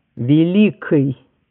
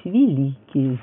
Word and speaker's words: великий: Вот произнесение твердых заднеязычных согласных в прилагательных мужского рода единственного числа «великой» или «великий» твердого или «мягкого», да? Когда-то оба таких варианта были равноправными. Два варианта нормы. В Москве произносили «великой», «громкой», «звонкой», а в Петербурге «великий», «звонкий», «громкий».